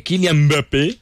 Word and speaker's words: Kylian Mbappé: Mire, hay otra cosa y es que Kylian Mbappé. – Sí. – ¿Está bien pronunciado? – Claro.